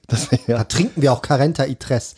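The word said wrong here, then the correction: cuarenta: Carenta. – Heißt das nicht Quarenta? – Ich kann Qua nicht gut aussprechen, aber sag ich Ka.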